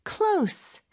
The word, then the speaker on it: close: 나중에 발음할 때도 얘기는 하겠지만 close, C-L-O-S-E 이 단어가 발음이 크게 두 가지가 있어요. 하나는 close, S 발음, 그리고 마지막으로 하나는 close, Z 발음이 있죠. 근데 여기서는 close, S 발음을 꼭 해주셔야 돼요. 이게 S 발음이냐 Z 발음이냐에 따라서 풍사가 완전히 달라지거든요.